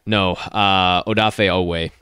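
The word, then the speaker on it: Odafe Oweh: And then we'll see as far as these defensive end additions with Awafe, or I want to make sure I say that right. I'm going to have to hear it a couple times. – Madubuki? – No. Odafe Owe. Odafe Owe.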